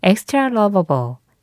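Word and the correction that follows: Xtralovable: 엑스트라 러버블 프린스 엑스트라 러버블이었습니다. 아예 그 스펠링에서 대문자 X를 쓰고 T-R-A-L-O-V-A-B-L-E 다 붙여서 써요.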